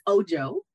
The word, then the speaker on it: Ojo: Well, it's Ojo. It's Ojo. The hard J. It's a Nigerian last name.